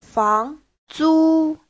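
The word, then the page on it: fangzu: fang2zu1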